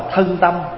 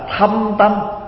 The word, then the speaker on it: thâm tâm: Có nhiều chỗ đọc là thân tâm nhưng mà không đúng, phải đọc là thâm tâm, tại vì nó khác.